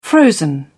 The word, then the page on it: frozen: British English